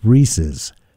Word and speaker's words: Reese's: Kristen just sent a text and says, please get to pronounce Reese's correctly. – Reese's. – It's Reese's. Kristen, I have tried for years to get to say it correctly, and she just refuses.